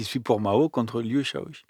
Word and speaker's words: Shaoqi: Shaoqi. Ah, merci. – Liu Shaoqi. – oui, excusez-moi, la prononciation de, la lettre Q se fait chi.